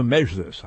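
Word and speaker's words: Mejlis: Now, note, front and center here is the Mezhlis. I may be mispronouncing that. The M-E-J, as in John, L-I-S, a Crimean Tatar organization that is banned in Russia and supported by Berlin, enjoys the support of some Crimean Tatars and not others.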